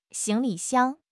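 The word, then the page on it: xing2 li5 xiang1: tone 2; tone 5; tone 1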